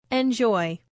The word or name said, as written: enjoy